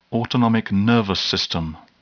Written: autonomic nervous system

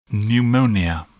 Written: pneumonia

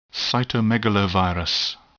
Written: cytomegalovirus

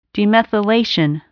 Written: demethylation

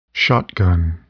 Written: shotgun

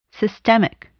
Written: systemic

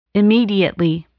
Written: immediately

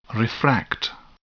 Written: refract

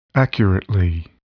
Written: accurately

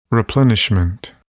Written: replenishment

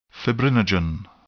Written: fibrinogen

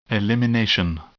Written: elimination

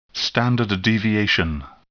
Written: standard deviation

